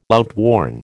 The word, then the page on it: outworn: American English